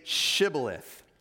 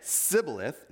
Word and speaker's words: Shibboleth: If he answered, "No," they told him, "Please say Shibboleth." If he said Sibboleth, notice the difference, because he could not pronounce it correctly, they seized him and killed him at the fords of the Jordan.